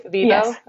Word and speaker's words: Vivo: Am I pronouncing that right? Vivo? – Yes.